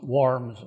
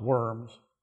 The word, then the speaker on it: Worms: So, summoned by the Holy Roman Emperor Charles V, who was the major secular authority, to appear in the city of Worms, it's pronounced Worms, not Worms,